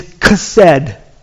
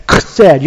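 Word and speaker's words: chesed (חסד): It's actually like that, chesed. You have to say it like you're clearing phlegm out of your throat. It's the way it's said. It's commonly translated in Scripture as loving kindness, this chesed love.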